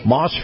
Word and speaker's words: Maus: Now, I want to remind everybody that Chris Markowski, the watchdog on Wall Street, his segment is brought to you by Moss Family Automotive, and in particular, the Moss Acura dealership. Now, Moss is spelled M-A-U-S, and I keep thinking to myself, gee, we've got people coming down here from all over the place buying vehicles.